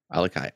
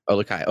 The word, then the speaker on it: OluKai: Alukai. Alu. – I have no Olukai. – Olukai.